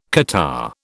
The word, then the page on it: catarrh: British English